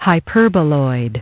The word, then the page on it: hyperboloid: British English